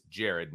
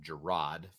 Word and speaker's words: Jared: And one of the bonuses for Jared Parker is that, and it's Jared, not Gerard, for all of those who don't know how to pronounce names like the radio I was listening to on the way home.